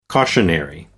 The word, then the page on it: cautionary: American English